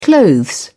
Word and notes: clothes: British English

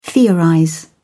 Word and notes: theorize: British English

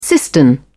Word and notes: cistern: British English

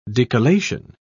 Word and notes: decollation: British English

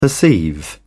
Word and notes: perceive: British English

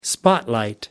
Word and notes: spotlight: American English

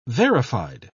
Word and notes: verified: British English

